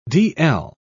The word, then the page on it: dl: British English